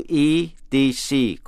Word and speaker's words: etc.: Chữ ETC có thể đọc là and so on hay là etc.